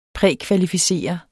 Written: prækvalificere

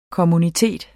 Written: kommunitet

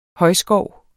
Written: højskov

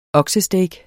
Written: oksesteak

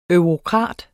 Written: eurokrat

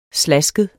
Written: slaskede

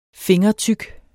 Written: fingertyk

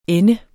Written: ende

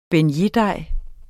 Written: beignetdej

